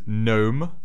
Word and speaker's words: gnome: Alright, well, my fourth word is gnome. Playing with the G there, silent G this time.